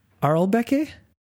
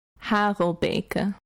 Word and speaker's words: Harelbeke: It is E3 Aarlbekke. – That's close. So if I were to pronounce it in Dutch, it's E3. Harelbeke. E3 Harelbeke. – E3 Harelbeke. – Beke. – Beke. – Yeah. Harelbeke. Pretty good.